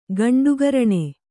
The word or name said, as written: ಗಂಡುಗರಣೆ